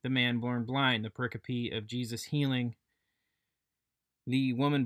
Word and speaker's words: pericope: And it is not, as I have pronounced it, pericope or perkiop. Pericope.